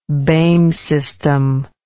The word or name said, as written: Boehm system